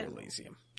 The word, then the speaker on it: Disco Elysium: Looks like Disco Elysium. Is that how you say it? – Disco Elysium. Yep.